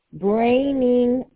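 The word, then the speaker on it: Breinig: The address is 8732 Braining Run Circle.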